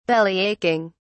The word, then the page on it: bellyaching: British English